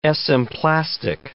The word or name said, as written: esemplastic